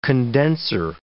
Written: condenser

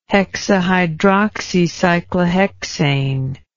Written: hexahydroxycyclohexane

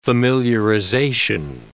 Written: familiarization

